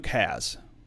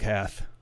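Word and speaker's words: Hasz: Don Eldred says, practice your C and Z pronounced as th. So like for German. So Luke, it's Luke Has, if you're talking about that. Not Luke Hath. H-A-C-Z.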